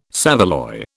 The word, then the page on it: saveloy: British English